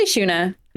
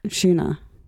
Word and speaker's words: Shuna: And also, do you say Shauna or Shuna? – I say Shuna. – Shuna. – We say Shauna.